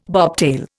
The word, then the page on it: bobtail: Indian